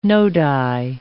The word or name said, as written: nodi